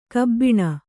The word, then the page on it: ಕಬ್ಬಿಣ: kabbiṇa; Kannada